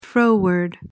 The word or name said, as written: froward